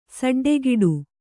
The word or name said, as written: ಸಡ್ಡೆಕೆಡು